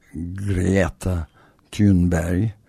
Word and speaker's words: Greta Thunberg: 23 Eylül tarihinde de Greta Thunberg, İsveçli genç aktivist ya da Greta Thunberg, İsveççe söylemeye telaffuz etmeye çalışırsak, o da konuşma yapacak.